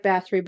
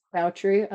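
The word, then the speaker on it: Báthory: It looks like Bathory, but it's actually pronounced Bowtry.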